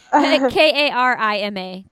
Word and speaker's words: Karima: K A R I M A. Karima? Is that what you would say? – Karima. Uh, Karima. Yeah, that sounds about right.